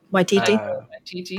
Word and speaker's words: Waititi: And Tracy, if you didn't know, that's I'm gonna mess up pronouncing his name, Taika Waititi.